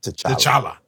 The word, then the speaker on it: T'Challa: But inside the letters, you see the late, great Chadwick Boseman in some of his iconic roles, James Brown, Jackie Robinson, T'Challa. I said that right? – T'Challa.